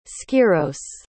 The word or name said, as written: skyros